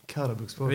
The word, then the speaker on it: Karabükspor: Karabükspor heter hon. – Mm. – Karabükspor. – Det är korrekta uttalet.